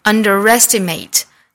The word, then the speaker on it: underestimate: 第二个词 underestimate, 这个词是一个多音节单词，我们注意重音的位置是在 re 这个音节上。 Underestimate, 哒哒哒哒哒 underestimate.